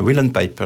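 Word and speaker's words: uilleann pipe: Whelan-pipe. Alors, Whelan-pipe, c'est la cornemuse irlandaise, qui a une particularité, un peu comme la cabrette dans la région, qui est la poche est gonflée par un soufflet.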